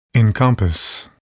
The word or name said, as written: encompass